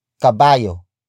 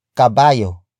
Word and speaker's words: kabayo: And that is kabayo. Kabayo. Kabayo. Kabayo is spelled as K-A-B-A-Y-O.